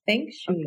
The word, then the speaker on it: Feng Shui: Y ella es consultora de Feng Shui. ¿Se dice así? – Sí, Feng Shui.